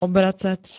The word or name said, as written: obracať